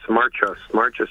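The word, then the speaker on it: Warchus: There's a fellow named his name is, rhymes with Fuss. Marchus is his name.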